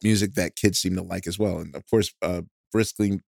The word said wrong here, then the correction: Blitzkrieg: I can't pronounce it. – Blitzkrieg Briskling Bop.